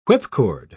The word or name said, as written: whipcord